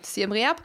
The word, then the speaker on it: Siem Reap: Siem Reap wird es auch auch richtig ausgesprochen, okay, top. – Genau, ähm, kannst einfach so aussprechen, wie es da steht. Okay, Siem Reap.